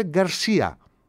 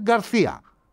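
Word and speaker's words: García: Όταν βλέπετε το σε, προφέρεται Γκαρσία. Άμα είναι κάτω, δηλαδή χαμηλά, Σεβίλη, ξέρω εγώ, Μαδρίτη και όλα αυτά τα πράγματα, είναι Γκαρθία. Γίνεται θήτα. Έτσι.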